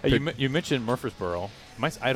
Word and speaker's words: Murfreesboro: Murfreesboro. – Murfreesboro. – Yes. – It's exactly how it looks. – Exactly how it looks. – Okay. When I hear Murfreesboro, I think of David Price.